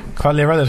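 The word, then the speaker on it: Quagliarella: I'm going to go for Cagliarella, is that how you say it? – Cagliarella.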